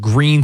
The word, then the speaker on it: Gruene: No, I swear When my wife's from New Braunfels, Texas, and there's a really cool little town called Green, Texas. It looks like it's spelled Gruene, G-R-U-E-N-E.